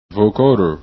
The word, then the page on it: vocoder: english